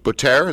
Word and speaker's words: Butare: At first, my parents didn't realize that they were also in danger, because in the Butare South, I don't know if I pronounced that right, Butare, is that how you say it? – Mm-hmm, yes.